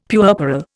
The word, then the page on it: puerperal: Indian